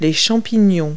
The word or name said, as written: fungi